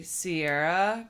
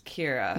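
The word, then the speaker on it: Ciara: You would say Sierra because it's C-I-A-R-A. Yeah. – Yeah. – But it's pronounced Kira.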